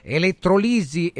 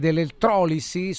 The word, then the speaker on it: elettrolisi: Allora attenzione, fermi, un altro ancora, eh, ma io lo so perché elettrolisi ed elettrolisi sono pronunce entrambe corrette.